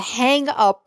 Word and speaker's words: Hanggeuppe: Okay, so the next one, okay, it's called the Hang Up, but it is spelled HANGGEUPPE.